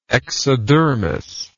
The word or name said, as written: exodermal